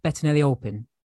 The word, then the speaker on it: Bettinelli-Olpin: And director Matt Bettinelli-Olpin.